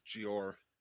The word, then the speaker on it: Jeor: It is Jeor. J-O-R. J-E-O-R.